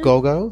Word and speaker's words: Gogo: Gogo. So G O G O, and then Morrow, like tomorrow, but Morrow.